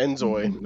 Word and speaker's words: inZOI: How do you say that? Inzoi? – Yeah, Inzoi. That's how you pronounce it.